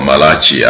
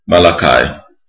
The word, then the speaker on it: Malachi: Zechariah before Malachi, or Malachi as it is pronounced in some areas, Malachi.